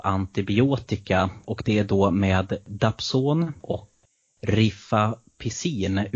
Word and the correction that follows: rifampicin: Uttalar jag det rätt där? – Rifampicin kanske? – Ja. Rifampicin.